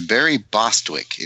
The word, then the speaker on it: Bostwick: I could be saying his name wrong. I pronounce things. – Yeah, that's what I heard about you. It's Barry Bostwick, and he played a character called Ace Hunter.